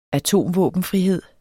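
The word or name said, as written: atomvåbenfrihed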